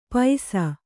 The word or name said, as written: ಪೈಸಾ